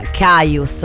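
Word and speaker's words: Kyuss: Beh, intanto K-Y-U non sono tre lettere che troviamo spesso nella lingua inglese. L'ufficialità è però quella, si pronuncia Caius, ok?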